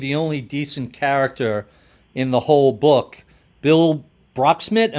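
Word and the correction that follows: Broeksmit: Am I pronouncing his last name right? – Brokesmith, yeah.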